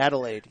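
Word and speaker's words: Adalaide: I think the Adolescent Bird, or if I'm pronouncing her name wrong, I forgive me. – Adelaide, yeah.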